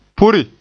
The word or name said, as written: puri